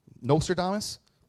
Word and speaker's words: Nostradamus: Who's the person that used to, Nostradamus? Nostradamus, is that how you pronounce it? Yeah. Nostradamus? Got it, thank you.